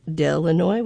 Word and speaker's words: Delanoy: Kenneth Deloy, Delanoy, which rhymes with Illinois, which is a web pledger from Spokane, Pat Moberly from Deer Park, Washington, and Deborah Rush from Spokane.